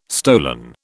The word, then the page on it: stolen: British English